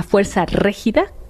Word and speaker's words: Fuerza Régida: El grupo se llama Fuerza Régida, así se pronuncia.